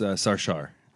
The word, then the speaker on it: Sarshar: No, not many people know, but it's Sarshar.